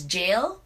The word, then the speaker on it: gaol: It's spelled G-A-O-L, and I just talked to you about this. So apparently it's pronounced also as jail, but spelled G-A-O-L.